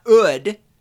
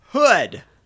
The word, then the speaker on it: hood: And you do pronounce the H. It's not ood. I mean, all right, maybe in Brooklyn or something. Hey, go look under the ood. An ood in my car. Hood. H. Ha, ha, ha.